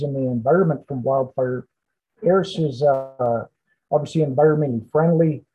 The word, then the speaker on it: ERIS: So let's talk about ARIS, E-R-I-S. Did I say it correctly? – Yeah, ARIS, oh. ARIS, yep.